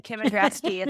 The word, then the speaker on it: Kim Endraske: Well done, Kim Endraske.